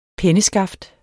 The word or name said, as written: penneskaft